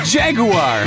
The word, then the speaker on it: Jaguar: Also, you get the proper English pronunciation of Jaguar.